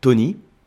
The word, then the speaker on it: tawny: l'approche oxydative, c'est le style Tony, donc ce qui s'écrit T-A-W-N-Y. Hein, on est sur un podcast audio, donc ne pensez pas que ça s'écrit euh, T-O-N-Y. donc T-A-W-N-Y. Et puis vous avez le style Ruby, R-U-B-Y.